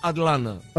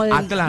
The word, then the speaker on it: Atlanta: Acá si lo querés pronunciar bien, tienes que decir Atlanta. – Atlanta. – Como consumiéndote el ta, Atlanta. – Claro, casi que es un na, Atlanta.